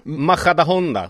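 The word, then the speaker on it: Majadahonda: Han avslutade ju med 6 matcher i spanska Maja Honda eller något. – Nej, men nu slarvar med uttalet. Det borde ju vara Maja, Maja da Honda.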